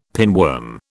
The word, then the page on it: pinworm: British English